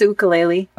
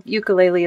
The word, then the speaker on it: ukulele: There's two pronunciations. The Hawaiian pronunciation is ukulele. – Okay. So I try to honor that, but it's so often called ukulele as well.